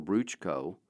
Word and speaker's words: Bruchko: Olson's story is so amazing that when it was put to book form with the title Bruchko, that's how the Motolone pronounce Bruce, it became a classic for people studying to become missionaries.